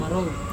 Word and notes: maro: Serewen

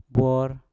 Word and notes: bwor: Mpur

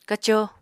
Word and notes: kaco: apatani

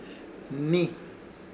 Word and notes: Hnii: Ishir Chamacoco

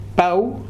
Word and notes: pau: Futuna

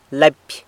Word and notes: lapy: sanlucasquiavini

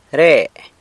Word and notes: re: sanlucasquiavini

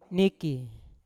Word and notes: niki: Yawa

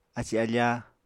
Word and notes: aci-afá: Apatani

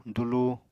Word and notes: dulu: apatani

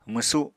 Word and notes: mwsu: Apatani

